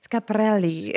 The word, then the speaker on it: Schiaparelli: Schiaparelli.